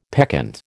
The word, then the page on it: peccant: American English